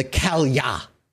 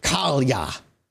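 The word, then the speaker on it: Cailleach: This next one is called the Kalya, even though it's spelt Kaleach. That's how it's spelt, in case you want to look it up. – Kaleach. – It's pronounced Kalya.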